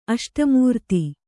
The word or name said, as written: ಅಷ್ಟಮೂರ್ತಿ